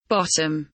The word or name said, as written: bottom